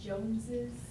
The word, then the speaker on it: Joneses: I thought it was just Jones, but wouldn't it be like Joneses? – Uh-huh. So we've added another syllable, so that means we're not just adding an S, we're adding ES.